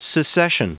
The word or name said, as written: secession